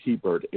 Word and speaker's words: Hebert: If one were to just do a web search for Family Caregiving with Warren Hebert, and that last name is spelled Hebert, H-E-B-E-R-T,